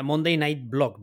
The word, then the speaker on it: Monday Night Blog: Monday Night Blog, ¿verdad? ¿Lo digo bien? – Lo dices perfectamente, sí, correcto.